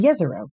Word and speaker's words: Jezero: Conceivably, microbial life could have lived in, Jezer- in Jezero. See, it's very easy to, to mispronounce.